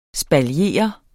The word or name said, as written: ESPALIERE